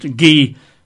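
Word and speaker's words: gi (γῆ): G-E, pronounced gi.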